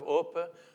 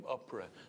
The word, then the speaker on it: Orpah: Unfortunately, the family couldn't pronounce it properly, and so she became Oprah.